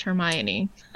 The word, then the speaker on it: Hermione: I mean, it took J.K. Rowling like 4 books to be like, oh, it's pronounced Hermione.